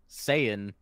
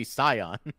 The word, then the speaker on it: Saiyan: Dragon Ball's actually done the same thing because, like, notoriously they pronounce Saiyan wrong. Yeah, it should it should be Scion.